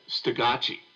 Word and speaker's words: Stugatche: It doesn't have a first name, but it's spelled S-T-U-G-A-T-C-H-E. – Okay. – So I've heard different versions. As near as I can tell, I just went into, you know, I don't think it's a real name. – Okay, all right. – I think Block made it up. And so I pronounced it in my head Stagacci. – Okay. – I've heard other people just pronounce it Stagacci.